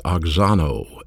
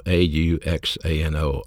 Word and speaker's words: auxano: This verb grow is pronounced auxano, A U X A N O, auxano, translates grow.